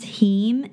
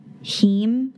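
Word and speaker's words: haem: So there's an element of blood which is called, I think it's pronounced heme. It's H-A-E-M, heme, which like, also comes like hemophilia.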